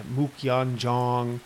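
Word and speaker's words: Muk Yan Jong: Number three is Buji practice, B-I-U-J-E-E. And of course, the fourth would be the dummy, which in Cantonese is pronounced Mukyan Zhong.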